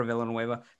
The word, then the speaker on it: Villanueva: They're losing Villanueva. – You said it right. – Okay. Yeah. Alejandro Villanueva.